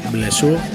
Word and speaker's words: Blessure: Es el 7 pulgadas compartido entre los y las bilbotarras Blessure, como se pronuncia en francés, y los madrileños, bueno, los vascos, medio madrileños afincados en Madrid, Golpe de Gracia.